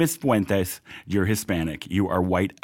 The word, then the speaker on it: Fuentes: Fuentes. – Fuentes. – Like it's supposed to be pronounced when you're, you know, a Latino immigrant, yeah.